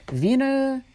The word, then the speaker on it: Wiener: but it is the Wiener Konzerthaus, which I think that's literally just concert house in Austrian German. And Wiener, W-I-E-N-E-R, I'm pronouncing as a V because I think that's what they do over there.